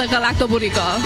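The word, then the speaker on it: Galaktoboureko: And she said, if you say garlic bread to go really quickly, like garlic bread to go, it sounds very similar to the Galactoburico.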